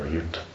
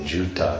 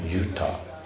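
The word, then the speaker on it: yūtha: Yuta. But if you say Juta, and then it becomes shoes or whatever, you know, Juta. So it's Y long U, Yuta.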